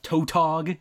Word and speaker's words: tautog: There is some colloquially known one called the Tautog, T-A-U-T-O-G, I'm assuming that's how you pronounce it.